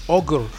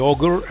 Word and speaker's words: ohGr: Ogre, que para quien no lo sepa, en el 2001 empezó a lanzar, Nive Cogre empezó a lanzar proyectos como solista bajo el nombre de Ogre, que se escribe O-H-G-R.